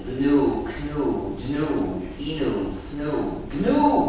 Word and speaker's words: GNU: Mais quand c'est le nom de notre système, il faut ne pas suivre la prononciation dans le dictionnaire. Il faut dire GNU.